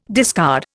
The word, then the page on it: discard: Indian